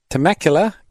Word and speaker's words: Temecula: All right, we got Steve listening from Temecula, California.